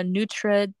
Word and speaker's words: Nutridyn: Nutridin I think is how you say it, is N U T R I D Y N.